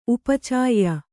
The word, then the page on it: ಉಪಚಯ: upa caya